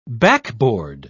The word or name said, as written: backboard